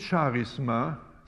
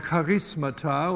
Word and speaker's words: Charisma: Übrigens nicht Charisma, sondern das griechische Wort beginnt mit einem Ch, mit einem Buchstaben, der wie das deutsche CH ausgesprochen wird. In der Mehrzahl lautet das Wort Charismata und deshalb der Ausdruck charismatisch, wonach die Bewegung genannt wird, weil sie so starke Betonung legt auf diese Begabungen.